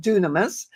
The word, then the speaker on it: dunamis: Now, the word power in many New Testament verses is derived from the Greek word dynamis, or it's pronounced dunamis, and it refers to God's power in the scriptures and means inherent power.